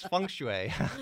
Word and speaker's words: Feng Shui: Well, you know, I can't pronounce it. I can spell it, but it's Feng Shui, right? – Yes.